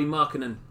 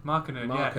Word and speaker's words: Markkanen: Oh, Lauri Markkanen. – Markkanen.